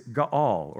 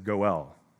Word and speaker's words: Ga'al: It is Ga'al, or you may have heard it mispronounced as Goel.